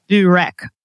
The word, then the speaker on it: byrek: is called byrek. It's spelled B-Y-R-E-K.